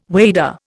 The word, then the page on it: wader: Indian